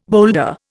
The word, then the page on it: boulder: Indian